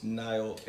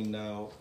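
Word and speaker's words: Nile: Again, my name is Niall, X-Niall, or you can just call me Niall, or you can just call me X for sure, it don't matter.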